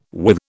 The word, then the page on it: with: British English